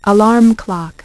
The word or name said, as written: Alarm Clock